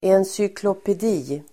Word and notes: encyklopedi: ensykloped'i:

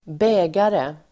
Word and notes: bägare: ²b'ä:gare

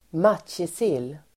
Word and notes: matjessill: [²m'at:jesil:]

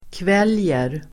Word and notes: kväljer: [kv'el:jer]

